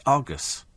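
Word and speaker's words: Argos: And Tony says, Argus. It's Argus. He says, I'm now nervously awaiting a tongue lashing. Go away, you silly little person. It's Argus. Everybody knows how it's pronounced.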